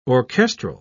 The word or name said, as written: orchestral